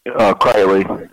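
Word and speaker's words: Kreiley: Kiley is how you pronounce the last name. – Kiley. – Yeah, it's K-R-E-I-L-E-Y.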